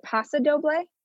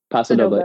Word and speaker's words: Paso Doble: But I always think of, is it Paso Doble? Is that how you say it? – Yeah. – Paso Doble.